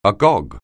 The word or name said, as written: agog